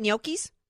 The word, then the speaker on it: gnocchi: Okay, well, did I pronounce it right? Gnocchis? – Yeah. – Okay. – You did. You got to put your tongue on the top of your mouth.